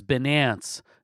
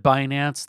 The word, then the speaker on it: Binance: There's Coinbase, there's Binance. If you pronounce it Binance, that's just fine.